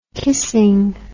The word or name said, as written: kissing